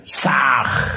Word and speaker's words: Sah: Ham escribe con las letras H-A-M. Sah se escribe con las letras S-A-H. La H suena siempre como J.